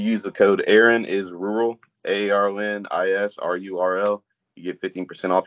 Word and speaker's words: RURL: But I also have a sponsorship with a brand rural around the area, RURL. Pronounced rural but without the A, and they have a lot of, if you like this hat, they got a lot of gear just like this, shirts.